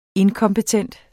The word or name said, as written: inkompetent